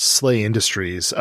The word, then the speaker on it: SLA Industries: So SLA Industries, pronounced Slay Industries, came out in 1993 from Nightfall Games.